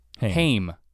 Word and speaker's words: Haim: But if you listen to the first, I actually don't know, who, is it Haim or Haim? How do you say their name? – Haim.